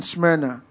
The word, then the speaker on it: Smyrna: Smyrna is spelled S-M-Y-R-N-A, Smyrna.